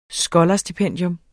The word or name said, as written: skolarstipendium